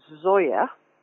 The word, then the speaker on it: Zoje: So there's a very good new debut novel called Bad Apple by a writer called, her name is pronounced Zoya Stage. So it's spelt Z-O-J-E and then the word Stage.